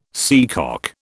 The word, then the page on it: seacock: British English